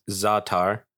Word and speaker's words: za'atar: Up in, it is pronounced za'atar, za'atar, za'atar. It's telling me za and tar.